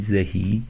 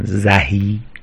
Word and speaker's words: زهی: زهی، اول از همه این زهی به همین صورت تلفظش درسته و این که میگن زهی غلطه.